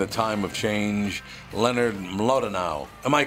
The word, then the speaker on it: Mlodinow: I would say Mlodinow. The W at the end sounds like a V, but that's just a little trick of my European ancestors. – Oh, Mlodinow.